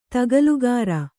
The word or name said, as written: ತಗಲುಗಾರ